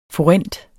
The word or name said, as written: forint